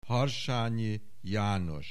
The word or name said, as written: Harsanyi Janos